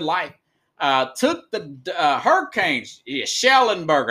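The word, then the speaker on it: Schnellenberger: Speaking of Lombardi, Carlos, Pickles, Howard Schnagenberger, Am I saying his name right? Former University of Miami Schlingenberger. Howard Schlingenberger. It's a mouthful.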